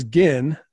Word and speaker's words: Ginn: His last name is Ginn. – Mm. – with a soft G, so you'd be like Rotten Cotton Gin.